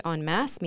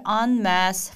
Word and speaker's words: en masse: on 이라는 발음, en 이라는 발음은 뭐 봉투의 envelope처럼 on 이라는 발음으로 네, 해달라고 하면 된다고 나오미가 얘기를 해주셨습니다.